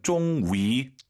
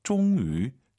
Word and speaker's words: 终于: 자, YU 발음 쫑위 다 알아들어요. 근데 입술을 동그랗게 말아서 쫑위, 쫑위 한번 해보세요.